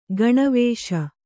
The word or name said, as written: ಗೋನಿವಾಸಿ